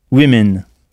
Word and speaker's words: women: De la même manière, une femme se dit woman, W-O-M-A-N. Et des femmes se dit women, W-O-M-E-N. Alors attention à bien prononcer ce pluriel-là. women. Est-ce que vous pourriez me le redire? – Women. – Women. Avec deux sons I, alors que ça s'écrit W-O-M-E-N.